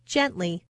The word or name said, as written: gently